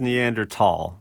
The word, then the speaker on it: Neanderthal: Is it Neanderthal or Neanderthal? I feel like there's some current debate here. – Yeah, I mean, it is Neanderthal, right? That would be the correct pronunciation.